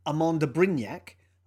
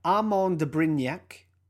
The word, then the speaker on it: Armand de Brignac: LVMH's wine and spirits arm, Moët Hennessy, recently bought a 50% stake in Amont de Brignac, a champagne brand owned by which entertainer? – Okay. – So Amont de Brignac, which is spelt A-R-M-A-N-D-D-E-B-R-I-G-N-A-C, is a champagne brand owned by which entertainer? – So Sam Neill owns wineries, right?